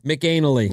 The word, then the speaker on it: McAnally: How would you say that name? Mac McAnally. – McAnally.